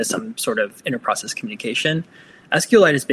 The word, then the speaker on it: SQLite: Yes, it's SQLite, kind of like a mineral.